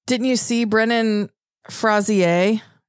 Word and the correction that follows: Brendan Fraser: Brendan Fraser, apparently we've been saying his name wrong all these years, but he's in the new movie The Whale, and he wore what is called a fat suit.